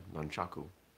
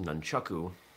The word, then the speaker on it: nunchaku: So I pronounce it, you know, based on my understanding of the Japanese term, nunchaku. I've also heard it pronounced nunchaku, which I'm pretty sure is not true, not correct.